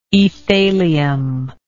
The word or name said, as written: aethalium